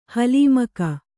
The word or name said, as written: ಹುಲಿಮುಖ